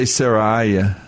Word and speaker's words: Seraiah: I say Saraiah. – I will say it that way. – Because it's like Jeremiah.